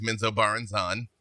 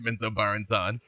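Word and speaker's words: Menzoberranzan: two of the main nuggets of joy that I got from my conversation with Bob Salvatore, which was tremendous, to pronounce the drow city as Menzo Barenzan, which is, yep. That's a thing now. Didn't know that. That's a thing. And I'm doing my best to spread the gospel that is pronounced Menzo Barenzan.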